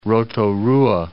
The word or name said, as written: Rotorua